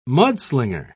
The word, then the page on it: mudslinger: British English